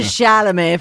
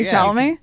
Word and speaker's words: Chalamet: And Mr. Chalamet from the Upper West Side. – That's where you are, Timothy, with two E's and a doulot on top. – And a silent T at the end of Chalamet.